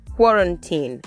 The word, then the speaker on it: quarantine: And then to the most common word in this period, quarantine. Quarantine. And this word could also be pronounced as quarantine. Quarantine. So quarantine. And you want to note the stress. The stress is on the first syllable. First syllable.